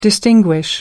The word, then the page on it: distinguish: American English